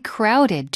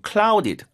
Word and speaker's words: crowded: 이 R 발음이 어려워서 이거 clouded, clouded 이렇게 발음이 자주 되는데 그렇게 발음하면 안 되고요. Crowd, 우리 약간 ra 발음으로 crowded 발음 도와드릴게요.